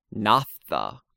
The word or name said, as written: naphtha